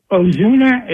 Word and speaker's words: Ozuna: Well, they got this guy, Marcel Ozuna. Is that how you pronounce it? – Yeah, who's a stud who can hit home runs.